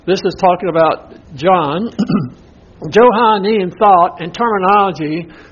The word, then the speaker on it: Johannine: Now, this next word is pronounced Johannin. You see that? J-O-H-A-N-N-I-N-E.